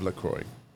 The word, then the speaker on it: Lacroix: Love LaCroix. – Es el episodio así. – Ah, Está cabrón porque de verdad el nombre es Lacroix, pero nadie lo quiere pronunciar.